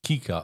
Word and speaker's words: cicer: Und zwar auf Lateinisch heißt die Kichererbse eigentlich Cica, also C-I-C-E-R. Und ausgesprochen wurde es Kika, also K-I-K-E-R.